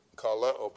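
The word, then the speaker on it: Kaleo (καλέω): That is a long O. Okay? And this is a short E. – Kaleo, but the accent's over the E.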